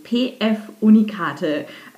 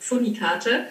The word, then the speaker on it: PF Unikate: Äh, du hast immer gesagt Pfunikarte.